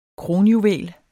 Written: kronjuvel

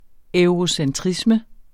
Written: eurocentrisme

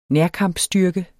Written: nærkampstyrke

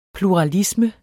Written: pluralisme

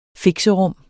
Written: fixerum

